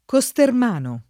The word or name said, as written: Costermano